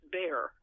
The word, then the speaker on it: Bejar: And I may lapse and simply call it Bejar, which is now pronounced Bear. The jota in the middle of the word is simply ignored and dropped. So it's we are in Bear County now instead of Bejar County.